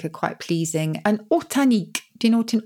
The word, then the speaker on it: ortanique: O-R-T-A-N-I-Q-U-E. – Oh, Hortan. Hortan. What does Hortan mean? Or Horta, and the nique is on the end, like picnic.